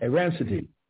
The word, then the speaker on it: Errançities: How do you pronounce that? Arancity. – Arancity.